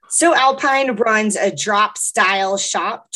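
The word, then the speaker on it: Sew Alpine: We'll be talking to Hannah Trim, the founder of a Colorado-based cottage company called So Alpine. – Hannah defines the name of her one-woman show by saying So Alpine is a pun, because it's spelled S-E-W.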